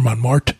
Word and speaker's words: Montmartre: Montmartre. – Okay. – Or Montmartre, depending on how you want to pronounce it.